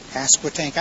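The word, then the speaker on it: Pasquotank: Passepartout. I was wondering if you were going to pronounce that properly. – If you live there long enough, if you don't know how to pronounce it, you're in trouble.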